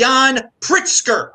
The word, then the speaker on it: Pritzker: You can type in Bitcoin Meister or do a super chat, and it's pronounced Jan Pritzker. I butchered his name at first, but we're gonna jump into this.